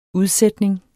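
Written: udsætning

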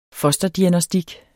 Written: fosterdiagnostik

